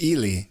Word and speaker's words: Ely: Could it be Eli? References. I don't know. – It's Ely is the pronunciation.